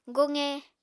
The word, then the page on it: goqe: Apatani